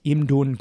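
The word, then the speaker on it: Imdun (임둔): The Lintun Commandery, um, in, pronoun- in Korean, it's pronounced Imdun Gun,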